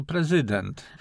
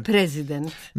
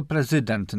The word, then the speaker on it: prezydent: Dla ludzi, którzy się kierują zdrowym rozsądkiem i elementarną wiedzą o języku polskim, a zatem po prostu prezydent. – A nie prezydent? – Nie, oczywiście, że nie. Tylko prezydent na drugą sylabę od końca, tak jak rezydent albo inne wyrazy o podobnym zakończeniu.